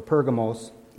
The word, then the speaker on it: Pergamos: And today, we're going to be looking at verses 12 through 17, which is Jesus' church, letter to the church of Pergamum, or Pergamos, either pronunciation is correct.